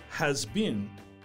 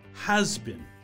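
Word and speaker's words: has-been: So its pronunciation is not uh, has been, but has been.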